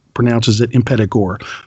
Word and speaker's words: Impetigore: It's Impetigore, and I know some people say that you should pronounce it Impetigore or Impetigore or whatever, but I've watched many a interview with Joko Anwar, and he pronounces it Impetigore. So guess what? So am I.